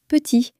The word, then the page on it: petit: french